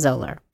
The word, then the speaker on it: Zahler: It's Zahler is how you spell Zoller, by Zoller.